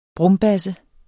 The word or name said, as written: brumbasse